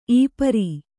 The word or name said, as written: ಈಪರಿ